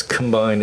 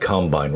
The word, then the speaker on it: Combine: Yeah, which, now, I guess we should just, we'll get to this later, but it's Combine, it's not Combine, right? – Yeah, definitely.